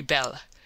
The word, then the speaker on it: belle: A poilus, Mabel, is a French peasant girl, and they say that they are very belle. Now don't mispronounce things and get sore till you know. You pronounce that like bell and push button.